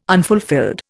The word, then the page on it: unfulfilled: Indian